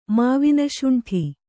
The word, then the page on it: ಮಾವಿನಶುಂಠಿ: Kannada